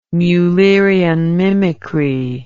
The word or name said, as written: Mullerian mimicry